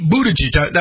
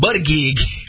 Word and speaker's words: Buttigieg: Pete Buttigieg. I'm finally saying his name right. I was calling him Buttigieg.